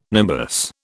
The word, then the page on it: nimbus: British English